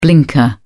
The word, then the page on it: blinker: British English